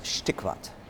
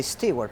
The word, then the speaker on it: Steigwart: Like, in German, it's Steigwart. – Steigwart. – In Argentina, from where I am, they say Steigwart because they make it a little bit like American.